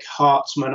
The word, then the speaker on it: Hartman: Eric Hartman, I always have to be careful I say how I say his name so it doesn't sound like Eric Cartman, has done a beautiful limited tribute for issue four of Ruby, painted.